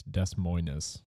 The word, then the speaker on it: Des Moines: But it's Des Moines.